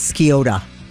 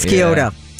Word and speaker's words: Scioto: Now, my lady who does my GPS says Scioto. – Scioto. – It's Scioto. Yeah. – At the conclusion of... That was a rough morning right there, kids. Yeah. Scioto.